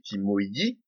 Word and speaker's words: Timoji: Timoji, ou comme on devrait le prononcer en portugais Timoidi, de son vrai nom Timaya, T-I-M-M-A-Y-Y-A, arrive dans notre récit dès 1498.